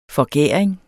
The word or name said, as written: forgæring